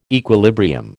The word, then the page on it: equilibrium: American English